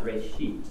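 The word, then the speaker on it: reshit (ראשית): From a Hebrew word, it's pronounced reshit, and it just means beginning.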